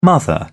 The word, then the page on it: mother: British English